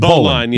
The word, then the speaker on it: bowline: Oh, but it's spelled bowline, but it's pronounced bowline.